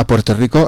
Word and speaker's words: Puerto Rico: A Puerto Rico, ells pronuncien Puerto Rico, perquè... no pronuncia les erres, no sé si heu conegut alguna vegada un puertorriquen.